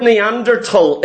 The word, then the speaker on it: Neanderthal: And notice here, the word Neanderthal is a silent H. It's not Neanderthal, it's Neanderthal. Not that it matters.